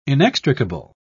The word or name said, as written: inextricable